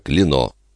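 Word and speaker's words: Klenau: По-французски его фамилия произносится как Клено.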